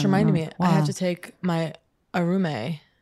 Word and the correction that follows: Aurum: Your Aurum? – Aurum, sorry.